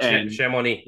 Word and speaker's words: Chamonix: For instance, this weekend we were in I don't know how to say it correctly, but Chamonix. – That's actually correct, Chamonix.